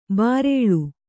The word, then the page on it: ಬರ್ಲು: Kannada